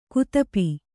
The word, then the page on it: ಗದಪು: gadapu